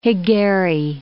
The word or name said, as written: hegari